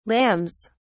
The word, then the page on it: lambs: British English